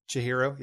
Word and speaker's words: Chihiro: Chihiro, yeah.